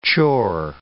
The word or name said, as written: chore